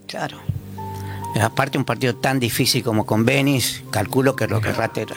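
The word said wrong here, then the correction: Benín: Benin. Con acento en la I. – Benin.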